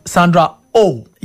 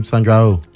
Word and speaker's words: Sandra Oh: And we have Quiz Lady with Awkwafina and Sandra Ho. To pronounce that O-H name, they do Sandra Oh. Yeah. – Oh, that's her name, Sandra Oh. – Yeah, Sandra Oh.